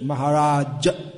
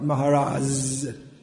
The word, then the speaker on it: Maharaj: Maharaj, not Maharaz. I wouldn't even know how to write that in Maharaj. How do you write that in English? There isn't any. It's anyway, it's not Maharaj.